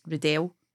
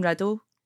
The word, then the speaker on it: Riddell: It's actually because my name's Riddell, so it's supposed to be pronounced Riddell. But fancy. – Like it's always just been Riddle, and it just feels a wee bit wanky to say.